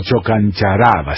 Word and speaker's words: Chocancharaba: Chocancharaba, o Chocancharaba, sí, con acento en esa penúltima A.